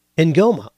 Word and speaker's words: Ng'oma: Vera's last name is NG'OMA. I can't even make an attempt to pronounce that. Ngoma.